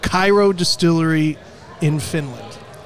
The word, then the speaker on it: Kyrö: So how do you pronounce this distillery? – It's Kyrö Distillery Company. – Kyrö. – Well, I'm fine with Kyrö. If you say Kyrö, we're good.